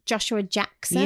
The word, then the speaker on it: Joshua Jackson: Joshua Jackson.